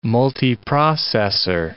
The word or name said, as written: multiprocessor